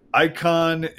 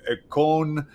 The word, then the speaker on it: icon: It's actually more like ikon, but we pronounce it, you know, that's what we do in America. We anglicize everything. So it's icon, and our theme has become trained readers reflecting Jesus.